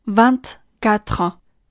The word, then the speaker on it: vingt-quatre: 我们首先需要注意的是，当二十后面跟上一到九之后，它原本不发音的这个 t 要开始发音。单独的二十是 vingt，但是二十四 vingt-quatre，二十五 vingt-cinq，vingt-cinq。